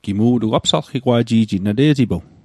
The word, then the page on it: apsalqigwa'ji'jit: apsalqikwa'ji'jit